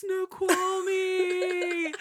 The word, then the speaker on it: Snoqualmie: it's Snoqualmie!